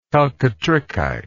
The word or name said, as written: chalcotrichite